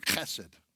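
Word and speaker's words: chesed (חסד): It's the word hesed. Hesed. Now, if it's pronouncing it in Hebrew, it'd be the word chesed, okay? You got to have that guttural, right?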